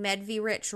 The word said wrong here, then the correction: Medvrich: wrong, and now I can't remember the correct way to pronounce it. – I was trying as it was coming out of my mouth. I knew that it was wrong because I'm like, why am I emphasizing the V? So you basically don't emphasize the V. It's just Medvrich.